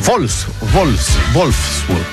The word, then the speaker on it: Wolfsburg: No, ese Wolfsburg, porque la W se pronuncia como V corta, ¿no? O V, ¿no? Se dice V.